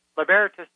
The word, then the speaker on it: Libertas: Libertas is spelled Libertas, L-I-B-E-R-T-A-S, Libertas Institute.